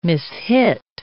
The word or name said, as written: mishit